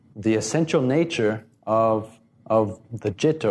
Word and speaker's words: citta: Again, I'll use the Pali term citta, C-I-T-T-A, but it's pronounced citta, and that means our mind.